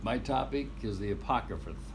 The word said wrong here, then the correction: Apocrypha: A-C-R-O-P-H-T-Y-A. – Apocrypha.